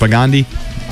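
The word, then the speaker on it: Propagandhi: Middle East has Propagandai, or I totally blew that name. – No one knows that pronounced. – Propagandi, Iron Chick, and Heart Sounds.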